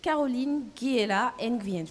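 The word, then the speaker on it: Caroline Guiela Nguyen: Caroline Guéla Nguyen, je le prononce bien ? – Ouais, super.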